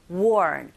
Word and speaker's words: worn: 그래서 the mask가 아니라 mask, the mask, worn. 발음하실 때 one보다는 wo 하면서 R 발음이 있기 때문에 worn, worn 이렇게 해주시고 by the players 하시면 되겠죠.